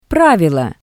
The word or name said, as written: pravilo